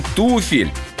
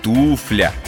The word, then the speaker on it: туфля: Не туфель, не туфля, а туфля.